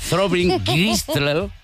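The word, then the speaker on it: Throbbing Gristle: Reich, que era el del grupo este de música industrial que se llama Thorin Gris, no lo sé ni pronunciar, porque se me pone la lengua como Paz Padilla. Thorin Gristle, era prácticamente ruido.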